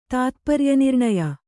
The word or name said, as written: ತಾತ್ಪರ್ಯನಿರ್ಣಯ